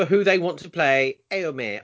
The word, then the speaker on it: Éomer: I've been saying that wrong for a very long time, but Carly told me it's pronounced Éomer. I've been calling it Omer, but no, Éomer.